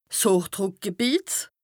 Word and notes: 's Hochdrùckgebìet: Bas-Rhin; Strasbourg

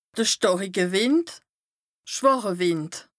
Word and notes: de stàrike Wìnd / schwàche Wìnd: Bas-Rhin; Strasbourg

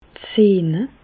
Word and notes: d' Sehne: Alsatian; Bas-Rhin; Reichshoffen

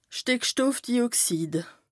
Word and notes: Stìckstoffdioxid: Bas Rhin

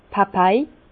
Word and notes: d' Papai: Bas Rhin; Herrlisheim

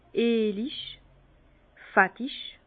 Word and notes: eelig, fettig: Bas-Rhin; Herrlisheim

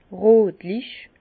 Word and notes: rötlich: Bas-Rhin; Herrlisheim

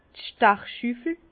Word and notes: d' Stachschüffel: Bas Rhin; Herrlisheim

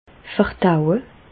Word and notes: verdaue: Bas-Rhin; Reichshoffen

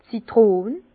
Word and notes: d' Zitrön: Bas Rhin; Herrlisheim

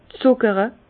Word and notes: zùckere: Bas-Rhin; Herrlisheim

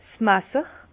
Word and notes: 's Masser: Bas Rhin; Herrlisheim

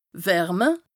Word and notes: wärme: Bas-Rhin; Strasbourg

